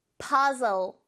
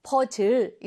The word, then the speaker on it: puzzle: 오케이, puzzle. puzzle이라고 하는 단어인데요. 사실 이건 우리말로도 퍼즐이라고 많이 하잖아요. 발음을 여러분 퍼즐, 퍼즐 이렇게 하지 말고 z, z 이 발음 잘 살려서 puzzle, puzzle, puzzle 그렇게 발음해 보세요.